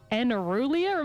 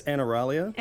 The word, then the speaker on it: aneuralia: which is something called aneuralia. Am I saying that right? – I would say it's aneuralia. – Aneuralia.